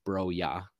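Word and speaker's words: Broja: And my bench is Sanchez, Broya, who I also looked up. It's Broya. That's how you pronounce his name.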